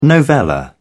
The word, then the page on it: novella: British English